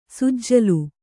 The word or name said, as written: ಸುಜ್ಜುಲು